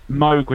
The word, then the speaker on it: Moog: Moog was supposed to rhyme with Vogue. That was where it came from. He was like, that's how you're supposed to pronounce it.